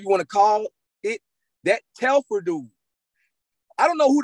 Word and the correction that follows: Telfar: Telfar, is it? Am I pronouncing it right? Telfar. – Telfar.